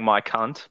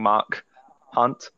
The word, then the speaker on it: Mark Hunt: It sounds like I'm saying Mike Hunt, but I'm saying Mark Hunt.